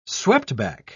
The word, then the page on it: sweptback: British English